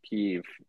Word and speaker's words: Kyiv: Like, these people are just living in this modern metropolis one day in Kyiv. I don't know if I'm saying that right. – No, you were saying it like a very sophisticated guy.